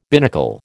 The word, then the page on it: binnacle: American English